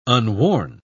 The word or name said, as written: unworn